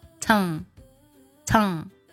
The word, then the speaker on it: tongue: 我们还是先发 T, 最后呢嘴巴打开，把这个长音读出来，读作 tong tong, 这样去读。